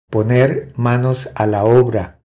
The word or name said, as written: Poner manos a la obra